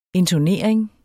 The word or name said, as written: intonering